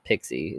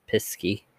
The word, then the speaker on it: Piskey: The Piskey runs, and I'm saying that exactly how it's spelled, not pixie, it's Piskey, runs rampant, causing mischief and mayhem along its path.